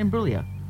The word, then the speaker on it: Imbruglia: Imbruglia. Yep, it's pretty close. Good. – Well, how do you say it? – Imbruglia.